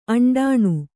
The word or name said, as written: ಅಂಡಾಣು